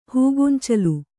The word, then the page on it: ಹೂಗೊಂಚಲು: kannada